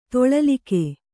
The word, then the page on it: ತೊಳಲಿಕೆ: toḷalike